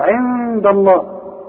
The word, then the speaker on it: عند: مرققة جات لي الدال جنب لفظ الجلاله ممكن تغلط وتقول لي عند الله عند ضا ضا